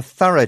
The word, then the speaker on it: thorough: And the O-U-G-H word there was thorough. T-H-O-R-O-U-G-H. It means very careful and exact. – And the pronunciation is different again. Mm. Listen to this one. Thorough. It's an uh sound right at the end of the word.